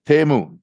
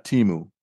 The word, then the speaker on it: Temu: Temu, not Timu. Mm-hmm. It's pronounced Temu. – Temu, well, right. – Yeah, T-E-H-M-O-O, Temu.